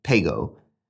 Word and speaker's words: PGO: which he signs with the initials PGO, pronounced Pago, as on his later paintings.